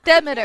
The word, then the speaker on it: Demeter: Say it wrong, Juniper. It's Demeter.